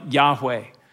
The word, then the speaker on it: Yahweh: In Hebrew, it's spelled YHWH, Yod Heh Vav Heh. And it means something along the lines of, it's pronounced something along the lines of, sounds like something like Yahweh.